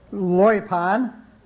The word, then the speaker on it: loipon: And there, the word is 3063, and it's spelled L-O-Y-P-O-N, or L-O-I-P-O-N, in an effort to pronounce it loipon, if you will.